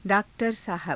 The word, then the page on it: _daak_tara_saahaba: Hindi